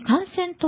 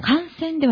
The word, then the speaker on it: 感染: まずちょっとイントネーションがちょっと違いますね。 – あの感染ではないんですね。 – 感染ですかね、やっぱり。 – 感染？ – はい。そのイントネーションだと、あの、バイ菌が悪さしている感染っていうような取り方にどちらかというと聞こえてしまうから。